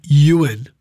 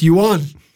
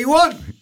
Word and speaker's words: Ewan: Yeah, no, it's Uon, but you might know me, people who really deep down know me, my name's actually Ewan, which is E-W-A-N, which is a Welsh name. But if you pronounce it in Welsh, it's Uon. Hey, Uon, what you doing?